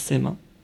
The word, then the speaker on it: sema: Ce qu'on appelle, nous, dans la tradition ottomane, le sama, on prononce sema,